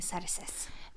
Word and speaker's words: Xerxes: Ja, og det har en kong Xerxes, eller det skrives jo med X, ja. – Men uttales kong Xerxes.